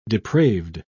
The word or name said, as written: depraved